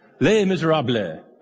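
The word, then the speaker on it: Les Misérables: Les Miserables. I actually heard like a French person say it, and they had the blah at the end. Les Miserables.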